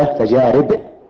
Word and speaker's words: تجارب: بتجارب، وأن التجارب تنطق بكسر الراء لأنها من الفعل جرب، مضارعه يجرب، مضارعه يجرب، ومصدره تجربة وليس تجربة بضم الراء، لا بكسرها، جرب يجرب تجربة، وجمع تجربة تجارب، تجارب. ويخطئ